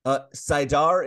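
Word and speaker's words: saidar: Sidar is correct.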